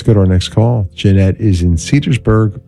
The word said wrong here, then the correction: Cedarburg: Wisconsin. Cedarburg. Did I say that right? Yeah, Cedarburg.